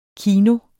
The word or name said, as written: kino